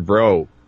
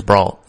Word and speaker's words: Brault: And then if they don't use him, that means Chad Kuhl is in, Drew Hutchinson is in, or Stephen Bro. It's gotta be Bro. – I think it's Brault. I think you pronounce the L and the T.